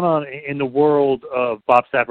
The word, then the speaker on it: Bob Sapp: Bob Sapp, welcome to the Two-Man Power Trip. – Oh, well, thank you very much. And you said it right, but it's Bob, the Beast.